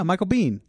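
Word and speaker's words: Michael Biehn: Michael Bain, whatever his name is. – Whom? – This guy. – Oh, Michael Bean? – Is that how you say his name? – Yeah, Michael Bean.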